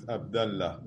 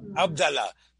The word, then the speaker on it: Abdallah: Anyway, one of these Sephardi Rabbanim, Abdullah. And I love this that he took on we talked about non-Jewish. – The right pronunciation is Abdallah. – Abdallah.